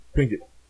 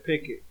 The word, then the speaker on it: Pinkett: Pinkett. I be seeing people posted her like Jada Pinkett, and it's Pinkett.